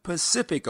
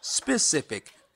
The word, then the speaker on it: specific: There's the Atlantic Ocean and the Pacific Ocean, but some of you pronounce specific as Pacific. The word is specific. The ocean is Pacific.